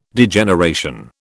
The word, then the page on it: Degeneration: British English